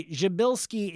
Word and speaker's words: Przybylski: So, by the way, Jabilski is spelled P-R-Z-Y-B-Y-L-S-K-I.